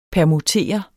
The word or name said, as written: permutere